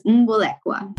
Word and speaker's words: Mbolekwa: Yes, my last name is Mbolekwa.